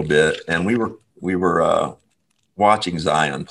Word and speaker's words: Zion: His name is, I think they pronounce it Zion Williamson. Zion, Z-I-O-N.